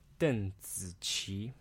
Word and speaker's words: Deng Ziqi: Her actual name is pronounced Deng Ziqi. – Deng Ziqi.